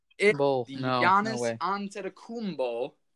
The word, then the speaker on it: Giannis Antetokounmpo: Giannis Antetokounmpo, pronounced that spot on, the Milwaukee Bucks.